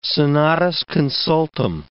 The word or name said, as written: senatus consultum